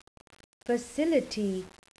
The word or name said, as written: facility